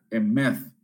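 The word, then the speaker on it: emeth (אמת): Cuando esta, esta palabra aparece eh, a menudo, es en el significado de la palabra hebreo que viene y se pronuncia emeth, que significa estabilidad, confiabilidad, verdad, certeza, fidelidad.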